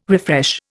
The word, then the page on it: refresh: Indian